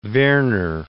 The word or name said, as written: Verner